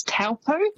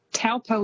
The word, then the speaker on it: Taupo: So quite often you'll hear that said as Taupo. – Yes. – It's almost how it's spelt, but that Taupo is actually pronounced Taupo.